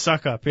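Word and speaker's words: Succop: You don't pronounce his name suck up, do you? – It is suck up, yeah.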